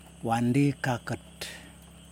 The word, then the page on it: wandikakut: Yokoim